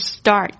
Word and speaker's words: start: 好，另外一个字是 Start Start S-T-A-R-T 开始，大家特别注意一下这个 A-R 的发音是 R R， OK，虽然在这个英式英语呢它可能也会发啊这个声音，就是那个 R 的声音不是非常的重，